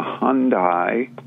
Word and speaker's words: Hyundai: It's the Hyundai, as it's correctly pronounced, Sonata Hybrid.